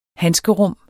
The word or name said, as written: handskerum